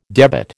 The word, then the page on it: debit: American English